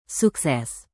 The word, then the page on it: sukses: Indonesian